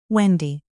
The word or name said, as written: wendy